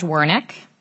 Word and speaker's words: Wernick: I'd like to call next Judge Wernick.